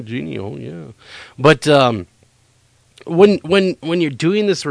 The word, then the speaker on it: jinni: I mean, shoved in with the devils and Ginny. Or how is that actually pronounced? J-I-N-N-I. – Genie.